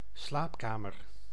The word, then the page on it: slaapkamer: [slaːp.ka.mœɾ]; /sla:p.ka.mœɾ/